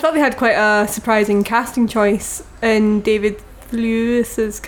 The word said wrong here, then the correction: Thewlis: character. I'm not Thewlis. – Saying this. We've just discussed it. I'm not saying this right. What? – Thewlis. – Thewlis. – Yeah. – David Thewlis's character.